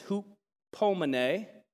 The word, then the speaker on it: hupomene: And it's pronounced hoop. You'd think hype, but it's hupomene.